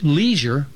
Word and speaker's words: leisure: Already that summer, I'd learned important words such as leisure, which Dad said was pronounced with a long E, but Mom said she liked a short E best.